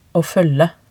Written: aa foelge

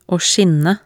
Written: å skinne

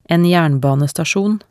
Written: en jernbanestasjon

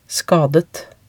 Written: skadet

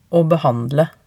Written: aa behandle

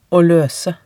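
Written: å løse

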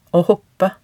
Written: å hoppe

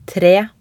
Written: tre

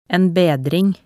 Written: en bedring

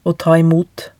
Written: å ta imot

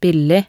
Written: billig

